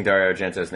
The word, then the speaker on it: Dario Argento: And probably completely mispronouncing Dario Argento's name.